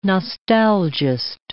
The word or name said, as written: nostalgist